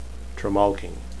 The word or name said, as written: tremalking